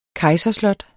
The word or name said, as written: kejserslot